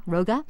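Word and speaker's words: Rogge: And as IOC President Jacques Rogu, did I say his name correctly? – Roga, said security has to be even more of the minds of everyone today.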